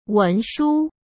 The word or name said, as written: wenshu